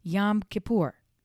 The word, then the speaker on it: Yom Kippur: So it got to be the one I thought it was, Yom Kippur.